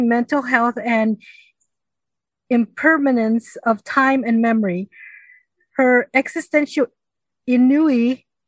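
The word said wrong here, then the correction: ennui: am I saying that right? I-N-N-U-I? – Anui. – Anui. Damn, I was way off.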